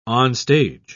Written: onstage

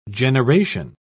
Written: generation

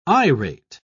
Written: irate

